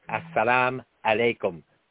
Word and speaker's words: assalamu alaikum: Well, the correct way to say that is as, which means the, salam, peace, assalamu alaikum, upon you, be upon you or unto you or with you.